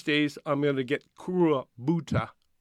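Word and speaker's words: Kurobuta: They say it like it's Crow Buddha.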